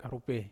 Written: karupe

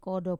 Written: kodo

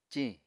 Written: ciñ

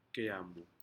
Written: Aneityum

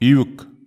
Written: iuk

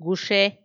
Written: guxe

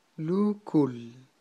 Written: lukul

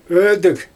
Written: ödIg

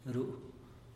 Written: ru'u

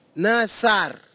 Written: nasar

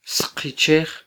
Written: sqichej

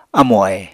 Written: amo-ahe

